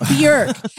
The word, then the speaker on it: Björk: It's pronounced Björk. Everyone says Björk, but it's Björk. It rhymes with jerk.